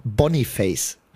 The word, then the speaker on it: Boniface: Der heißt einfach, no joke, und es wird auch so ausgesprochen, der heißt einfach Boniface.